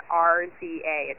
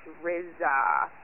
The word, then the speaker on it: RZA: I'm sure it was just a mistake, but you do not pronounce RZA of the Wu-Tang Clan, RZA. It's RZA. RZA.